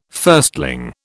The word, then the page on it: firstling: British English